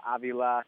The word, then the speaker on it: Avila: You notice how I pronounce that, Beck? Avila. His son is Avila. – That was a big step that they made here, was to make that more consistent so that Al and Alex both have the same pronunciation.